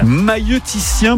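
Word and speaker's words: maïeuticien: Mailloticien, voilà. – Mailloticien, bravo à vous.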